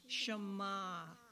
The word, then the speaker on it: Shema: Shema.